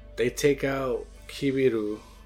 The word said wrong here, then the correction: Kibito: Kibito. You're pronouncing it wrong is making me pronounce it wrong.